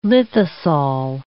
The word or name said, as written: lithosol